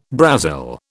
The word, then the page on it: Brazil: British English